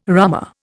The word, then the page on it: rummer: Indian